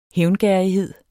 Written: hævngerrighed

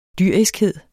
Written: dyriskhed

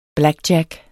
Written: blackjack

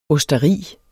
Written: OSTERIERNES

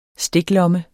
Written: STIKLOMME

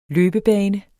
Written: løbebane

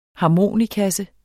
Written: harmonikasse

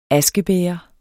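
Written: askebæger

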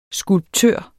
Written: skulptør

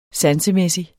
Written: sansemæssig

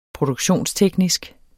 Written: produktionsteknisk

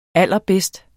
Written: allerbedst

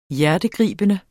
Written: hjertegribende

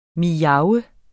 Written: miaves

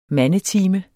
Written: mandetime

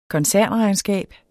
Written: koncernregnskab

